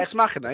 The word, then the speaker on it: Ex Machina: Ex Machina, yeah.